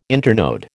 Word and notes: internode: American English

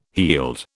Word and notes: heald: British English

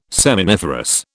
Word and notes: seminiferous: British English

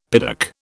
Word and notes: piddock: British English

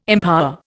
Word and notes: empower: Indian

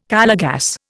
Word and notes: Calor gas: Indian

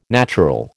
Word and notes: natural: American English

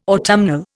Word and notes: autumnal: Indian

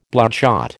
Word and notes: bloodshot: American English

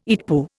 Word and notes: EETPU: Indian

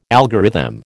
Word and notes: algorithm: American English

